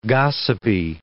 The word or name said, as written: gossipy